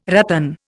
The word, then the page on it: ratan: Indian